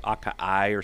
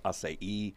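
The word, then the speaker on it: açaí: Some people say acai or something, but it's actually pronounced acai.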